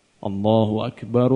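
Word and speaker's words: Allahu akbar (الله أكبر): Allahu akbar, Allahu akbar itu disambung, ya. Allah A, bukan O tapi A. Allahu akbar, Allahu akbar, tidak diputus. Allahu akbar, Allahu akbar, tidak. Ketika adzan disambung, Allahu akbar, Allahu akbar.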